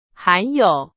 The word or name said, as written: 含有